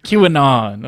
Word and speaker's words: QAnon: QAnon. Oh Lord. – Is that how you say it? – Yeah.